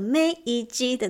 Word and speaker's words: 明治: 刚刚有提到明治时期，这个明治你知道怎么发音吗？我相信有在吃巧克力跟看电视的人，应该很快就可以答得出来。没错，就是那个梅伊基的那个梅吉，梅吉吉代。